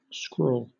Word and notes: scrawl: British English

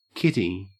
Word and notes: kitty: Australian English

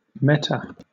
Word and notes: meta: British English

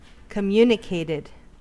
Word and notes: communicated: American English

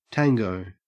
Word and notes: tango: Australian English

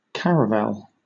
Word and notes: caravel: British English

